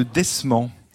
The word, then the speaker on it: desman: C'est le desmant. Oui, cet animal, le rat trompette. Ça s'écrit D-E-S-M-A-N, desman ou desmant, des Pyrénées.